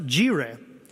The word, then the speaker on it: yireh: Now, here's a place where it's good to know a little Hebrew, because the word for the Lord will provide is the word yireh, or sometimes pronounced jireh.